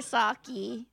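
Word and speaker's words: Socci: So just pour yourself a shot of sake and spell Saki right.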